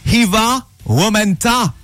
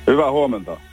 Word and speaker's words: Hyvä huomenta: Hyvä huomenta. – Hyvä huomenta. – Ah, ben voilà. Did I pronounce it correctly? – Yes, you did.